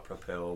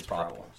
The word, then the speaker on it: Propel: Some people call it Propel, but you know. – Yeah, you're not saying it right. It's Propel. Propel.